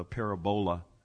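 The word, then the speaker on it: parabola (παραβολή): the parabola, which we said is the Greek way to pronounce that word, the word parable.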